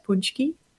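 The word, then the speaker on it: pączki: It still does to practice how to actually say it because it looks so different than the way you spell it. But punchki.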